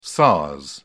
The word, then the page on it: sars: British English